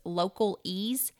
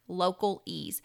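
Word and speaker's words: LocalEze: To me, the simplest one that he mentioned was Local Ease. It's spelled just like local, and then the last is E-Z-E, and it's one word, Local Ease.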